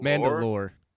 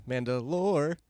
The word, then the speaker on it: Mandalore: Mandalore. – There you go. – Yeah, Mandalore.